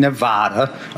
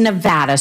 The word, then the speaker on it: Nevada: Point in Nevada. Okay, Nevada. It's Nevada, sir. Please say it correctly. It's Nevada.